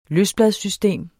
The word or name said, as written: løsbladssystem